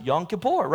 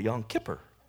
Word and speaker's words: Yom Kippur: Like the rabbi calls it, I used to pronounce it Yom Kippur, right? He calls it Yom Kippur. Sounds like Skipper from Gilligan's Island.